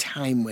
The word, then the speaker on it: thyme: Why do we call it thyme when it's T-H-Y-M-E? – It should be thyme.